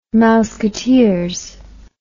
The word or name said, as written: mouseketeers